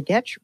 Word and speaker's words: Getch: My name is Sally Getch, rhymes with sketch.